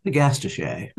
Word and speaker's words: agastache: But I don't like the way people pronounce it because I always really feel it should be agastache.